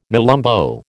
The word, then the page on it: nelumbo: American English